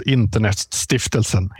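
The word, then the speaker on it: Internetstiftelsen: And I'm going to ask if you'll be gracious and pronounce the name in Swedish for me so I don't embarrass myself. – They're called Internet Stiftelsen in Swedish.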